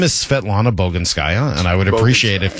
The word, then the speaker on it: Svetlana Boginskaya: Svetlana Boginskaya.